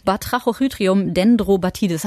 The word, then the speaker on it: Batrachochytrium dendrobatidis: BD, Batrachochytrium dendrobatidis.